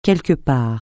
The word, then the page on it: quelque part: francais